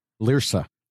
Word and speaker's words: Larsa: Is that how you say her name? Larsa? – Larsa.